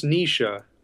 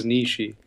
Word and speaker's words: Nietzsche: I always thought it was pronounced Nisha. I guess that's seemed to me like the German pronunciation, but everybody says Nietzsche.